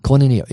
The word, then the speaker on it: koinonia: Koinonia in the Greek.